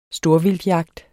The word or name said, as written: storvildtjagt